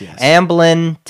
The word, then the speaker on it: Amblin: Amblin Television.